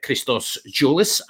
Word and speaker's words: Christos Tsiolis: But tell the Hodge on Nodge listeners why you can tell us about Christos Tsiolis and Dimitris Yiannoulis, and correct my pronunciation first off. – No, your pronunciation is spot on.